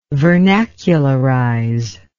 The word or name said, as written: vernacularize